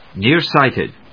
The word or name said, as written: Nearsighted